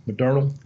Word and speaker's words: Moderna: Um, Modernal, Modernal, Moderna, it's Moderna, Moderna.